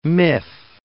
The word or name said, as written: miff